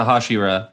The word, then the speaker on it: Hashira: I'm pronouncing it right? – Hashira, yeah. So yeah. When they meet up with the Hashira,